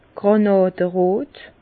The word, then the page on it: grànàderot: Bas-Rhin; Herrlisheim